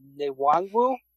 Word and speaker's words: Nwangwu: But now it looks like they have Kenny Ngong. I can't pronounce his Nwangwu. That's right. I think I got that right.